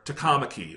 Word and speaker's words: Takamaki: And it's really, it just catches me off guard every time because I will see the name and I know Japanese pronunciation. I know that's Takamaki. – Yeah. – And they'll say Takamaki.